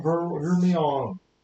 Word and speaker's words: Hermione: What's the deal with the name Hermione? And apparently it's Hermione. Why is it spelled like that? – Why do they spell it like that?